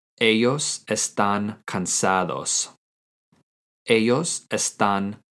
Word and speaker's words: ellos están: In some countries, it also might make a J sound, such as ellos or ellas. To say they are in Spanish using the verb estar is ellos están. Ellos están. This is spelled E-L-L-O-S space E-S-T-A-N with an accent on the A in están. Ellos están.